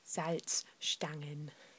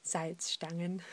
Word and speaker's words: Salzstangen: But if you want your salty snack, then you should pronounce it in German, which is Salzstangen. I'll say that again, Salzstangen.